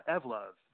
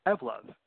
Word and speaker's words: Evlove: Yeah, Evlove. Think of evolve and love together. – Okay, so how do you pronounce it again? – Evlove.